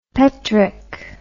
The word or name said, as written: paitrick